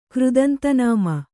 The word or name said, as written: ಕೃದಂತನಾಮ